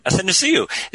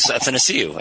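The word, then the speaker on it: Athanasiou: Athanasiu. And we had it in our locker room, I just rhymed it with happy to see you. Yeah. So it was Athanasiu, and I never got it wrong again.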